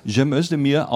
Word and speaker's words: Cem Özdemir: Achten Sie bitte, dass ich ihn besonders authentisch ausspreche seit neuestem Jahr. Cem Özdemir aus Bad Urach, so ein türkischer Einwanderer, der sagt, ich sage euch das schon seit Jahren, es will keiner hören.